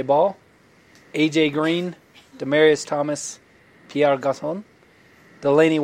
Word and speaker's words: Pierre Garçon: How do you say that? – Pierre Garçon.